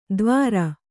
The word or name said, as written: ದ್ವಾರ